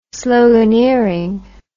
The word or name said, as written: sloganeering